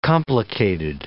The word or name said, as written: complicated